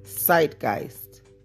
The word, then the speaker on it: Zeitgeist: Let me listen to it. – Zeitgeist. – Okay, Zeitgeist. Right, so that word is Zeitgeist.